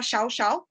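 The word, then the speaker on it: Llao Llao: se, escribe Yao Yao, pero se pronuncia Shao Shao.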